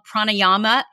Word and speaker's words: pranayama: And we would start and end every session with what they called pranayama. Is that, am I pronouncing that right? – Yes. Okay. Yeah, that's perfect. That's it.